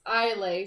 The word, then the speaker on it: Islay: Orla, the Princess of Gold, has shone brightly across Islay? – Isla.